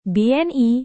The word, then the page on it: BNI: indonesian